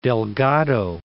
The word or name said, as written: Delgado, Cape